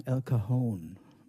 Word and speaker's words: El Cajon: Und in diesem Ort hat Johnny Mandel ein Stück geschrieben und das hat er genannt El Cajon und hat es aber immer ausgesprochen El Cajon, weil das war seine Hommage an Al Cohn, berühmter Saxophonist.